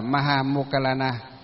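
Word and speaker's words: Maha Moggallāna: Duyên khởi của đại lễ Vu Lan được bắt đầu từ tôn giả Maha Mục Kiển Liên, tiếng Phạm âm đọc là Maha Mokkalana. Maha Mokkalana tức là ngài Maha Mục Kiển Liên,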